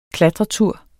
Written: klatretur

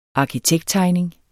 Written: arkitekttegning

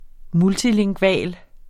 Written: multilingval

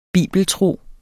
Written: bibeltro

